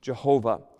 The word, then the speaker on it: Jehovah: And the pronunciation Yahweh is very poor and totally insufficient to overthrow the powerful and numerous evidences in favor of the pronunciation Jehovah. It is evident that Jehovah is the correct pronunciation of the name of God.